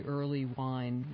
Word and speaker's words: Earleywine: Your book, again, is called Understanding Marijuana, A New Look at the Scientific Evidence, and I should say that your last name is spelled E-A-R-L-E-Y-W-I-N-E, Early Wine, Mitch Early Wine.